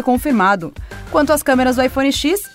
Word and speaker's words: iPhone X: O aguardado iPhone X, ou iPhone X, foi oficialmente anunciado, confirmando a pronúncia como iPhone X.